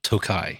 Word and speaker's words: Tokaji: I say Tokaji, but we've also seen... – We've also been told it's Tokai.